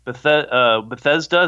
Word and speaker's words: Bethesda: Yeah, for the crime of Nazi killing game Wolfenstein being anti-Nazi, Bethesda, is I'm saying that right? – Yeah.